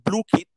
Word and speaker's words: Blooket: It's pronounced Blue Kit, but it's spelled BLOOKET.